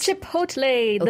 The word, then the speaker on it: Chipotle: Chipotle, the Mexican food chain.